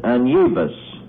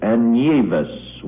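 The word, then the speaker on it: Anievas: His name is Augustin Anyevus.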